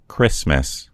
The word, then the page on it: christmas: American English